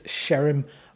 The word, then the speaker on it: Sherim: We hebben het hem expliciet gevraagd, hoe spreek je je achternaam uit? En het is Sherim, want zijn vader is Brits, dus ja, wordt het op zijn Engels uitgesproken.